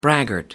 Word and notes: braggart: American English

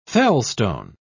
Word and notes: felstone: British English

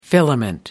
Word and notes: filament: American English

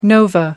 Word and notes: nova: American English

